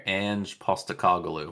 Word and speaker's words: Ange Postecoglou: Ange Postecoglou.